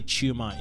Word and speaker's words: Tshuma: So which is the right way to say Tshuma? – Well, in Zimbabwe, we say Tshuma. – Ah, okay. – Yeah, but Tshuma, I think, is not incorrect.